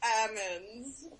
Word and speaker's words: almonds: Apparently, we're not supposed to use the L. – If you want to sound fancy and, like, pretentious, you can be like, yes, almonds.